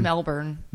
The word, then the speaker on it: Melbourne: Am I saying it right? Is it Melbourne or Melbourne? – I think it's just Melbourne.